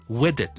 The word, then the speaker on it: wedded: Do take thee, 啊边个边个边个，to be my lawful wedded wife， okay， to be my lawful 合法嘅妻子，wedded 咧，wedded 咧个 ded 读埋出嚟嘅，另一个 syllable 嚟嘅， to be my lawful wedded wife， okay，所以成句嚟一次，I call upon all persons here present to witness that I 啊边个边个边个，do take thee 啊边个边个边个，to be my lawful wedded wife。